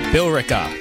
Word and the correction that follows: Billerica: How do you pronounce that? Billerica. There's an E in there. – Billerica. – Billerica, maybe? – Billerica. – Oh, that makes sense. Just leave out the E.